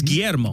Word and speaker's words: Guillermo: My first name is Guillermo.